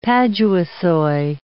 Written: paduasoy

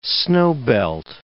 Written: snowbelt

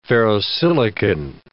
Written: ferrosilicon

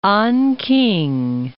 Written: Anking

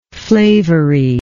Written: flavory

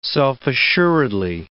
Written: self-assuredly

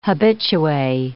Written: habitue

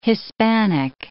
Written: hispanic

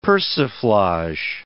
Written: persiflage

